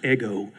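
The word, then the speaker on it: ego (ἐγώ): E-G-O. It's a literal, that's a literal transliteration word. E, it's pronounced ego. It's not the thing in the toaster.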